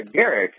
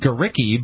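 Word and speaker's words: Gericke: Although I'm not sure if I'm saying her name right. – That's how I've always heard it said. Yeah. I know that there are some people that pronounce it Garicki, but I don't think that's how she would pronounce her own last name.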